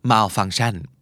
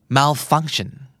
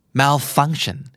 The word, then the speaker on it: malfunction: ภาษาไทย malfunction ออกเสียงทุกพยางค์เท่ากันใช่ไหมครับภาษาอังกฤษ malfunction malfunction มันจะเป็นตะตะตะตะตะตะเสมอ